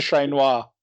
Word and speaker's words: Ché Noir: So shout out to Che. – Well, if you're going to do a shout out, Matt, you should say her name properly. So shout out to Ché Noir.